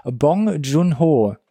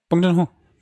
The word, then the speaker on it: Bong Joon-ho: ein Film von Bong Joon-ho. – Ja, Bong Joon-ho.